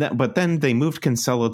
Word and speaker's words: Cancelo: It was like a weird, like, it was like Kyle Walker played for a half and is it Cancelo? Cancelo, however you say his name? – Cancelo, I think it Cancelo. – Is. – Cancelo, yeah. – Not that we've ever been right about pronunciations on this podcast, but I'm going Cancelo.